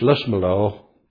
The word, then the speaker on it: Lisbelaw: On paper it's called Lisbelaw, but if you're about the town it's Lismelaw, and I'm sure it's the same with this particular town.